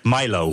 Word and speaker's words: Milo: Ja, nou, ik heb een beetje moeten lachen eigenlijk, dat is het beste woord, over die Britse ultra-rechtse influencer Mila Yiannopoulos, zo spreek je dat. Oh ja, ik sla altijd zijn achternaam over. En hier zegt iedereen altijd Milo. – Ja, Milo, ja, zo noemt je zelf.